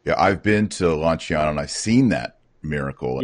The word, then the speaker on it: Lanciano: But what we see, the first one is Lanciano, or Lanciano is the proper pronunciation,